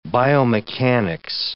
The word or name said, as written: biomechanics